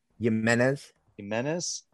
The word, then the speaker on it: Jimenez: Xavier J-I-M-E-N-E-S or N-E-Z Jimenez.